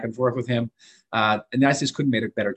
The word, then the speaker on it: Nides: I'll take no credit for that because his name is spelled N-I-D-E-S. Everyone said this is Nides and Nidus and they can't get it right. It's Knights.